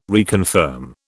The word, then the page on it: reconfirm: British English